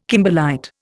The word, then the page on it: kimberlite: Indian